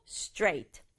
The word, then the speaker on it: straight: 주의할 점은 스트레이트 다섯 박자 아니라 한 박자로 straight. 해볼까요? straight. 이렇게 발음해야 된다.